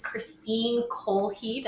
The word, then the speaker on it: Kristine Kjolhede: This is Kristine Kjolhede.